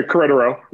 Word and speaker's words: Querétaro: Querétaro, did I say that right? – Yeah, Querétaro.